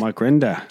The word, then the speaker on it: Mike Rinda: Mike Rinda.